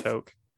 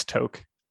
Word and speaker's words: toque: I thought it was pronounced toque, though. – I don't know. I just looked up the definition online and it's got like the solid... like the pronunciation guide is like a solid line over like the O.